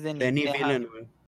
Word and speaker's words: Denis Villeneuve: película de este Denis Villeneuve, no sé, corríjanme si lo estoy pronunciando mal. – es, Villeneuve, ¿no? – Es Denis, ¿no? – Por lo francés. Sí, ¿no? – Es Denis, Villeneuve. – Vaya. – Tú eres el francés, a ver, amáganos. – Es Denis Villeneuve, pero.